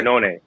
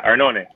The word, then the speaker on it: Arnone: First off, before I forget, your last name is pronounced Arnone. Is that right? – Arnone. Yeah, Arnone. – Of course it is, because you're Italian. I was saying Arnon.